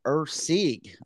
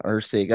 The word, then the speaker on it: Erceg: He will take on Steven Ercig, I believe is how that's pronounced. E-R-C-E-G. Yeah, Ercig. That's what I'm going to go with.